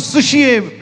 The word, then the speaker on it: psuche: It's pronounced as sushie, which the spelling is P-S-U-C-H-E, but it's pronounced as sushie.